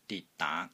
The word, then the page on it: DIT3 DAA2: Cantonese